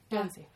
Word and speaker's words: Guernsey: Og Guernsey, er det riktig uttale? Det er riktig. – Guernsey.